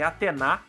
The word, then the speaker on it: Atena: O Porto do Pireu muito movimentado, por isso tem essa disputa entre a deusa Atena, na verdade a pronúncia correta é Atena, né, a deusa Atena e o deus Poseidon.